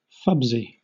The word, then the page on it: fubsy: British English